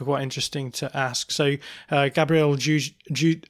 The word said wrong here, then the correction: Dudziak: I can never say his name, Dudziak. I'm going to say that again.